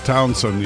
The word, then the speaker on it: Townshend: Do you pronounce the S-H? I just always say Pete Townshend. – Townshend, yeah. – Even though it's Townshend, that's how it's spelled.